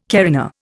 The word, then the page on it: carina: Indian